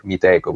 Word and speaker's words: Meetecho: But unfortunately, we were too ignorant at the time to realize that adding the H there actually made it make it sound like Miteco, which is how most people pronounce it right now. Even though actually we've heard a lot of different pronunciation, not only those. We've heard people call it like Micho, Mitecho, even Mastic ones, which surprised me quite a lot. But anyway, whatever you want to pronounce it, it's fine by us. I mean, Miteco is usually the way people pronounce it.